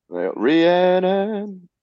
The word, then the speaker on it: Rhiannon: I never know how to pronounce this song correctly. Fleetwood Mac, Rhiannon, however the hell that song is pronounced. It looks like Rihanna, but spelled. – Rhiannon? – Yes. – Rhiannon. – Correct.